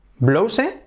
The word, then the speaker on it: blouse: Blouse es igual que house, que casa, house.